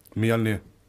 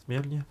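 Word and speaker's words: Mjölnir: Mjolnir. – Yes, Mjolnir.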